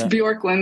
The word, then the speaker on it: Bjorklund: You already know how it goes down on the Unguarded, but Angie Bjorklund. – Oh, boy. – Did I get that right? – Close enough. All right. Like, it's Bjorklund. The J is silent.